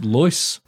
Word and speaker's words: Lois: Maybe it's Lewis, but it's spelled Lois. – It's Lois. – Lois Green. – L-O-I-S.